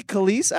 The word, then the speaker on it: Kelis: Really Khalees? After all these years, I'm finding this out? – Yes. How did you say it? – I said Keyless. Isn't it K-E-L-I-S? – He said, you know what?